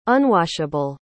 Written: unwashable

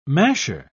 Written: masher

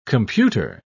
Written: computer

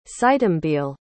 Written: sitembile

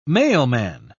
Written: mailman

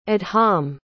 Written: adhamh

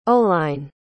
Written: Oline

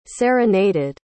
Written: serenaded

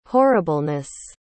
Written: horribleness

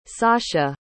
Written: sasha

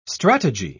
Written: strategy

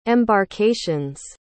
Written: embarkations

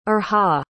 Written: irha

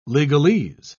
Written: legalese

